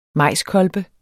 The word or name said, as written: majskolbe